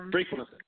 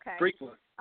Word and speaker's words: Frequency: And I hope I'm pronouncing it. It's called Frequent, right? And Frequency. – Oh, okay. – Frequency. – I was saying it correctly.